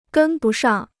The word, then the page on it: 跟不上: gēn bu shàng